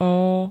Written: ó

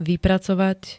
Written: vypracovať

